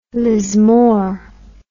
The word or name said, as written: Lismore